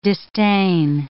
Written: distain

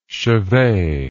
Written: chevet

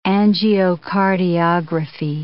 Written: angiocardiography

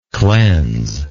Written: cleansable

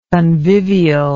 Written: convivially

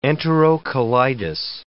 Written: enterocolitis

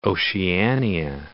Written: Oceania

